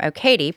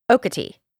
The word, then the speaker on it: Okatie: Yes, I was saying that John Marvin took some time to get to the crime scene because he was coming from Okatie, but it's actually pronounced Okatie.